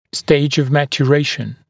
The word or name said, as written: stage of maturation